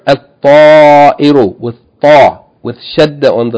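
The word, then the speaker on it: al-ta'iru (الطائر): Remember, the sun letters, when a word begins with a sun letter and you add alif lam, you don't pronounce the lam. Rather, you merge the lam into the sun letter and double the sun letter. So we don't say al-ta'iru, we say al-ta'iru, means the bird.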